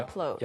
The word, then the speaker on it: upload: 네, 그런데 upload. 영어에서는 up, P 발음을 살려줘야 됩니다.